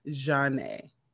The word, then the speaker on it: Zhane: So the name is spelled Zhane. So, of course, they had to put on the album, it's pronounced Jeanne.